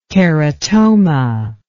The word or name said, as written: keratoma